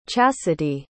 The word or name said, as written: chasity